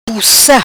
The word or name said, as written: Pussa